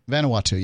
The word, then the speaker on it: Vanuatu: And which is part of the, how do you say this? Vanuatu? – Vanuatu, yes.